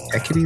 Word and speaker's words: Hecate: He pronounces it so fucking good, and I always see he cat in my head. Heck, I think he says Hecate. Hecate is the way it's pronounced.